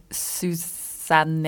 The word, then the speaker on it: Susanne: So my name is actually Susanne in German. a lot of English-speaking people get that wrong. – Oh, really? Okay. – So I never know how to pronounce it in English, so it's it's a whole thing.